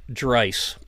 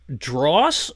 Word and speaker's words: Draiss: Oh, It's like my last name, it's pronounced Dreiss, and it the spelling is D-R-A-I-S-S. So it's been, we get the telemarketers calling and they go, Mr. Dross? And I said, you have one more chance to at least get it in the ballpark, because there is no way D-R-A-I-S-S is pronounced like D-R-A-W-S-E or something like that.